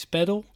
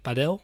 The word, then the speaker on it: padel: De Engelse versie is Padel. De Spaanse versie leggen de klemtoon iets meer op de A, dus die zeggen Padel.